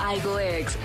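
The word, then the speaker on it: Aygo X: Toyota confirma el nuevo Igo X, el nombre del próximo crossover del segmento A. El nuevo modelo cuya X se pronuncia Cross pasará a engrosar la creciente oferta de crossovers de Toyota.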